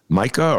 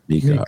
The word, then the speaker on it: Mika: Micah or Mika? Did I butcher it? – Mika.